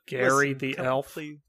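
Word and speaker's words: Ga'yriii: It's G-A apostrophe Y-R-I-I-I. – Mm-hmm. Something like that. – Mm-hmm. – Yeah, but it's pronounced Gary.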